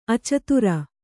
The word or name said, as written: ಅಚಿತ್ರ